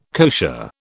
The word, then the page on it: kosher: British English